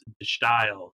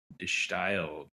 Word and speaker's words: De Stijl: This is an album I've been listening to for at least 15 years, and I never bothered to look up the pronunciation because it's a weird spelling. But the album is Die Style by the White Stripes. – Die Style. Yeah, man. I had to look that up, too. It's like D-E-S-T-I-J-L.